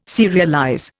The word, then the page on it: serialize: Indian